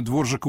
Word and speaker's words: Дворжак: Дворжак, так по-чешски пишется. Все равно все будут называть его Двораком, и он на это спокойно будет реагировать, особенно когда речь зайдет об английских гастролях и английских заказах.